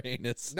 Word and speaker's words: Anis: How am I going to pronounce the name? – Anis. – Okay, Anis. – Anis. – Anis. There we go. I like Anis.